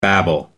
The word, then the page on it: babel: American English